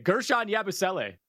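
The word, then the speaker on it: Guerschon Yabusele: Gershon Yabusele.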